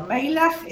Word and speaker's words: Meilach: Meilach es el nombre que está, por la CH se pronuncia como J.